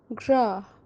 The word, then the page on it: Graah: kashmiri